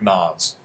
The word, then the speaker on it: Nas: Softer S, so like Nas. – Nas. It's voiced. The S is voiced. It's okay. – Like a Z.